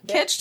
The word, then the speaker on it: Kitsch: Kitsch to look rhyme with like Twitch.